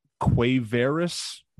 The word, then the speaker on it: Quavaris: Quavaris, which just sounds like it can't be the correct pronunciation, but that's how it's pronounced on the Tennessee website.